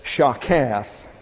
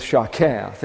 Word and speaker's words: shakath: And that word corruption is shakath. That's spelled S-H-A-K-A-T. But this right here is a CH concept, and this has an H to it. So we wind up with shakath, and it means to decay, ruin, corrupt, or destroy.